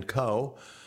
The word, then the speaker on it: Koh: Former Biden administration official Dan Coe, it's spelled K-O-H, is his last name,